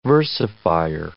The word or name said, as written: versifier